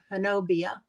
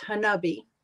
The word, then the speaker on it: Honobia: I know it's the name of the city is it's spelled Honobia, and you would think that it would be pronounced Hanobia, but Chuck said no, it's pronounced Hanubi, and it's a pretty infamous area for the Bigfoot.